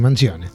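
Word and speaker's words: Manzione: No sé cómo se pronuncia eso porque no soy italiana. – Sí, pero es así, Mancione.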